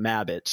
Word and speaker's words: Mabbitt: I figured that, I spelled it two B's, two T's, because everybody always spells my last name wrong. Two B's, one T, like they're trying to spell rabbit, but Mabbitt.